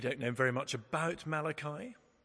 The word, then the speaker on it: Malachi: It's pronounced Malachi, not Malarkey, as somebody once pronounced him.